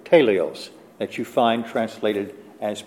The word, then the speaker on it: teleios: The Greek word for perfect there is teleios. We would spell it T-E-L-E-I-O-S, teleios.